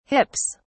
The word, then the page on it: hips: British English